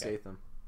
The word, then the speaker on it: Statham: Statham, maybe? Is that how you pronounce his last name? I forget. – I think it's Statham.